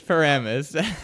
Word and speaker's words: Paramus: No, Paramus. – I always pronounce Paramus. – It's Paramus.